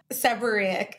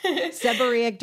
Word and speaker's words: seborrheic: Seborrheic. – Seborrheic dermatitis.